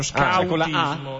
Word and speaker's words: scautismo: dello scautismo, e non so perché la Wikipedia me lo segna come scautismo, scritto esattamente come io lo pronuncio, scautismo.